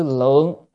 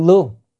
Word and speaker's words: tư lương: phải đọc là lương, còn đọc lượng á, là số lượng rồi, cho nên phải đọc đúng âm.